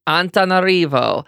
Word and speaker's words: Antananarivo: Antananarivo. – Antananarivo.